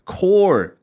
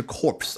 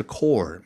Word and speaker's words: corps: 这个 core 可别读错了，不是 corpse，而是 core 啊。